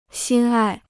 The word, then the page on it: 心爱: xīn ài